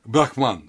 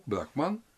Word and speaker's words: Брахман: К этому времени уже различаем, это и Сыркин предлагает делать, Брахма с маленькой буквы с ударением, Брахман с ударением на последний слог, Брахман, средний род, это вот этот безначальный высшее бытие, и Брахма ударением на первый слог, мужской род, это Бог-творец.